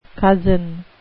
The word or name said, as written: cozen